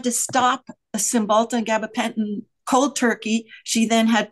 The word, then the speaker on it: gabapentin: But when you get, or serotonin syndrome, one of my friends was on gabapentin.